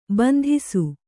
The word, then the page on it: ಬಂದಿಸು: bandisu